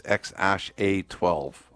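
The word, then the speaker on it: X Æ A-12: The pronunciation is X-A-12, I think.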